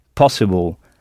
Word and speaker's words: possible: Oui, « possible », en insistant bien sur le « pas »,« possible ».